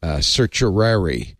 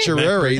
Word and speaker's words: certiorari: We talked about this yesterday, and I learned how to pronounce certiorari. – Is that how you say certiorari? – It? – Certiorari. That's what Jason Snell says is how you say it.